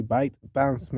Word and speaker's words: ByteDance: Recientemente se conoció que bueno, TikTok, una red social de China que pertenece a la empresa de BitDance o Bit, sí, así, ByteDance, mejor dicho, yo creo que se pronuncia así, ByteDance, está siendo bloqueada en muchos países del mundo.